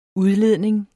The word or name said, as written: udledning